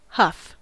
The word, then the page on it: huff: American English